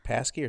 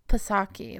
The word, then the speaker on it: Psaki: How do you say the speaker, the press secretary's last name, Jen Psaki or Psaki? – I think it's like Psaki. Like, I think you pronounce the P. – I thought the P was maybe silent.